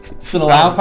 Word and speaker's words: Sinaloa: Sinalao? – Sinalao? Sinaloa cartel, but I looked it up because I was like, oh, it's kind of.